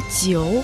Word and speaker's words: 酒: А jiu в третьем тоне означает вино, водка, алкоголь.